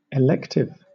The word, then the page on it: elective: British English